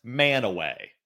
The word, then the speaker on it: Mantua: M-A-N-T-U-A, Mantua. – That makes total sense. No, if you are from there, you would know it is pronounced Manaway.